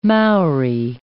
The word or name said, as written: Maori